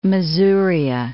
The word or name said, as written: Masuria